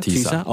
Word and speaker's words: TIISA: Do I pronounce it TISA? – TISA.